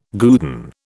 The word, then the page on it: guerdon: British English